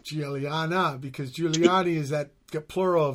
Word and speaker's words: Guiliana: And then we had Mark Guiliana with Julian, I should say. Yeah. Shit. – Yep. – Right. They have the soft G, huh? Giallo for yellow. – Yeah. Yeah, that Italian pronunciation.